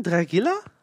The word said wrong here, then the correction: Drigalla: Drigalla heißt Drigalla.